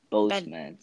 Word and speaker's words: Boseman: Det uh, detected to our friend by inspir inspiration and our hero uh, Cedric Bosman. – Boseman. Aussprache immer noch, ne? – Boseman, sorry.